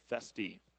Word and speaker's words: Foeste: Jack Festy, spelled F-O-E-S-T-E. Pronounced Festy.